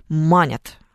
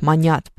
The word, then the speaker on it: манить: потому что все люди почему-то манят, а на самом-то деле они ж манят, понимаете? Вот должно быть правильное ударение.